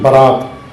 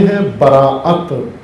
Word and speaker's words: براءت: شب براءت, لیلۃ البراءت, برات یہ اس کا عام تلفظ ہو گیا ہے, رائج برات. اصل میں ہے براءت, حمزہ ہے براءت, تبرہ, تبرہ سے زیادہ بہتر آشنا ہے آپ.